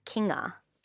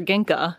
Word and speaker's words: Kinga: And her name is Kinga. – Kinga. But my mom, who does love to mispronounce things, calls her Ginka. – Yes, but it's like King and then and she's from Poland.